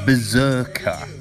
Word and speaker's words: BRZRKR: So it's based on a Boom comic book series called, I think you pronounce it Berserker, but it's spelled B-R-Z-R-K-R.